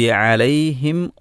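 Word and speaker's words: അലൈഹിം (عليهم): അൻഅംത അലൈഹിം ഗൈറിൽ മഗ്ദൂബി അലൈഹിം വലദ്ദാലീൻ. അലൈഹിം എന്ന് പറയുന്നിടത്ത് സുക്കൂനുള്ള മീമാണ്. അലൈഹിം എന്നതിൽ അവസാന അക്ഷരം ഏതാണ്? സുക്കൂനുള്ള മീമാണ്. സുക്കൂനുള്ള മീമിൻ്റെ ഉച്ചാരണം രണ്ട് ചുണ്ടും പൂട്ടുക എന്നതാണ്.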